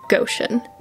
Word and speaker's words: Goshen: Not Goshen. Go like go, not gosh golly. Goshen rhymes with motion, notion, ocean, lotion, potion. It's pronounced Goshen.